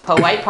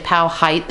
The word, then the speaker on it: Powhite: Your outside Richmond listeners need to know we have a Poite Parkway that is spelled Powhite. If you're not from Richmond, you say Powhite the first time. – Or if you're a GPS. – Then you figure out the locals are all calling it Poite.